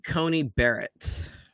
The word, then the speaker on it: Coney Barrett: Amy Coney Barrett.